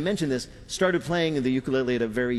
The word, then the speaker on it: ukulele: Peter's first question to Taimane, how the hell do you pronounce ukulele? – Upu ukulele. – Ukulele. – Uku. – Ukulele. Lele. – You got it.